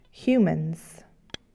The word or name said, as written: humans